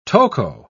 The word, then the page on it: toco: British English